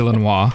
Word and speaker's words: Illinois: With no S on the end. If you have to pronounce it fancy, it's French, so it's Illinois.